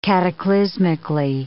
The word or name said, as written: cataclysmically